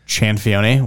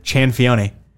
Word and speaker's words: Cianfaglione: Chanfione, which is C-I-A. – That's how you pronounce it? – Chanfione. – That sounds way sicker than how I thought it sounded. – You can find it. Let me just go through the entire alphabet here. C-I-A-N-F-A-G-L-I-O-N-E.